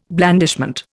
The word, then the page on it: blandishment: Indian